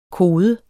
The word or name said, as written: kode